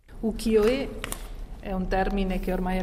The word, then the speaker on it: Ukiyo-e: È un termine che è costruito con tre caratteri cinesi che sono appunto uki, yo e, che significano immagini del mondo fluttuante.